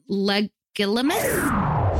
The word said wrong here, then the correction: Legilimency: Fact check. That is not the correct pronunciation. These words from the wizarding world of Harry Potter are pronounced Occlumency and Legilimency.